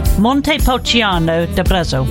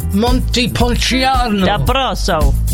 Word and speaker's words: Montepulciano d'Abruzzo: Montepulciano d'Abruzzo. – Yeah, just say Montepulciano. – Yeah, go on, you say it again. – It's Montepulciano. – No, it's Montepulciano. – Montepulciano. – D'Abruzzo.